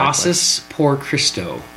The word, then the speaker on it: Casas por Cristo: Casas por Cristo.